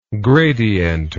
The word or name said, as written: gradienter